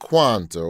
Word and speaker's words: Kwanzaa: Talking about Kwanzaa, or as I pronounced it the first time, Kwanzaa. I don't know why I added a letter, but that's what we do in Alabama.